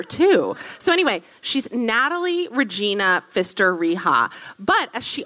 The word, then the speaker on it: Regina: Yeah, it's spelled Regina, but it's pronounced Regina.